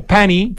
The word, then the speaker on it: Panny: Ya, y ese pan y circo, ojo que se escribe P-A-N-N-Y, como pani, sí.